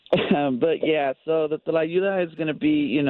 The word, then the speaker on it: Tlayuda: Tlayuda. – So I'm saying it wrong. So the T is not silent. – No, it's not. It's not per se silent, but yeah, it's, the T is very pronounced.